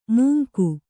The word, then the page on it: ಮೂಂಕು: mūnku